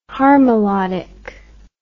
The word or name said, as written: harmolodic